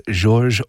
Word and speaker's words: Georges: from the late 17th century Baroque composer Georges or Georg Muffat, his name probably pronounced both ways since he was surrounded by both French and German-speaking musicians.